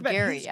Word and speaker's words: Gerry: I believe it's pronounced Gerry, actually. But yes.